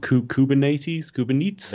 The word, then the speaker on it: Kubernetes: And a lot of the new tools that people are using, like Docker and like I'm going to pronounce this wrong, Kubernetes. – That's how I say it.